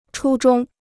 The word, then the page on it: 初衷: chū zhōng